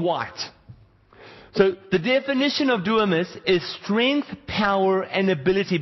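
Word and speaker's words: dunamis (δύναμις): The Greek word for power is spelled D-Y-N-A-M-I-S, and it is pronounced dunamis. Alright? The N is like a silent N, so it's dunamis.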